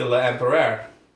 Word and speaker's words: L'Empereur: We meet the L'Empereur. How did you pronounce it? – L'Empereur? – Your pronunciation was spot on.